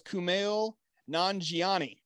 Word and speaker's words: Kumail Nanjiani: I'm going to try to get his name right. I tried to pronounce it out loud before we went live tonight. – Okay. – I believe it is Kumail Nanjiani. – Perfect.